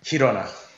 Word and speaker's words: Girona: Girona, am I saying that right? – Girona. – Girona. – There you go.